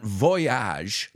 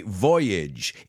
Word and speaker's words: voyage: And it's not voyage, like the song, voyage, voyage, and on and on and on, voyage, voyage. No, we say voyage. It rhymes with bridge, language, voyage.